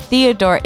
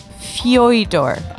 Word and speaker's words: Fyodor: His first name is not Theodore. It's Fyodor. Fyodor.